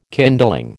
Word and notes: kindling: American English

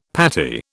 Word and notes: patty: British English

